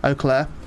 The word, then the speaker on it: Eau Claire: Eau Claire.